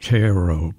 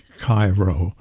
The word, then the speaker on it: Cairo: The Cairo that's in Illinois was pronounced Cairo, but Billy Murray pronounces it Cairo. Maybe it was Cairo way back then.